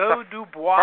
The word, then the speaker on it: Clos du Bois: By the way, I looked it up in our little wine pronunciation, and you are totally correct. – Oh, that's Clos du Bois. – First. That's a first.